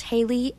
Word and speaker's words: Hayley: My name is spelled Hayley, H A Y L E Y.